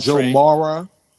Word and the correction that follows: Mauer: Joe Mauer.